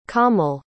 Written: kaamil

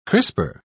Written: crisper

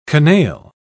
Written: canaille

